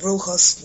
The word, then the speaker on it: brujo: But then, with brujos, no.